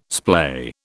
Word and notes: splay: British English

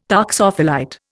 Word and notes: toxophilite: Indian

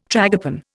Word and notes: tragopan: Indian

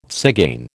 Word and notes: tzigane: American English

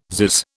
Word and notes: zizz: British English

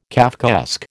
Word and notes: Kafkaesque: American English

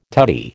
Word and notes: tutty: American English

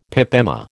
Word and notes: pip emma: American English

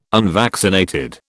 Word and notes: unvaccinated: British English

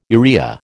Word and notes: urea: American English

